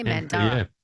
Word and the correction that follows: Mandurah: It's an indigenous name, and it's pronounced Mandura.